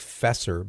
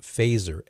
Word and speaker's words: Feser: It looks like Fesser, but it's pronounced Fazer, F-E-S-E-R.